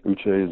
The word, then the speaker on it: Uche: And help me with the pronunciation of the last name. Is it Josh Uche? – Yes, Uche is it.